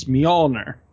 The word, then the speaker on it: Mjolnir: The J is pronounced like a Y, so it's Mjolnir.